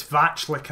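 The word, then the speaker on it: Vaclík: Is it Vaclik in goal? Vaclik? – Yes, it's Vaclik, I think, is how I've heard it pronounced by Spanish commentators.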